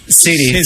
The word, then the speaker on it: City: Pero aparte, City lo pronunciaste, City. – Bárbaro. – City, claro. City. – Oh, yeah. – Kansas City. – Gracias. – Yes. – Mezcla de D y T, ¿no? – Claro.